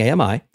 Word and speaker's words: AMI: Remember, two syllables wrong, three syllables good when pronouncing AMI.